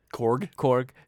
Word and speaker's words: Korg: Korg.